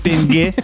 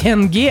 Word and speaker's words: тенге: Тенге? Что, о чем речь? Не понимаю. – Не тенге, а тенге вообще-то. – Тенге? – Говори, как нужно правильно говорить.